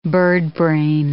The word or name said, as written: birdbrain